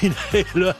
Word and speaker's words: Inhaler: Allez, on écoute Inhaler, My Honest Face, parce qu'on ne prononce pas le H de Inhaler.